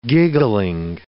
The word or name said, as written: giggling